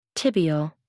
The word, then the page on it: tibial: American English